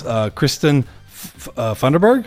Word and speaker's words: Kristin Funderburg: So, and Miss Kristin Funderburg.